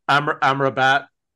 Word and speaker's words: Amrabat: Amrabat and Gravenberch.